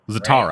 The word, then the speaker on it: Zatara: Zatara.